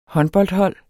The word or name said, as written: håndboldhold